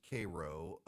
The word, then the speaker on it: Cairo: And there's a Cairo, Georgia, but it's pronounced Cairo.